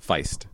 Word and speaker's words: Feist: Is it Feist? I don't know. – What? – I can spell it. – Feist? F-E-I-S-T? – Yes. – Feist? – Yes.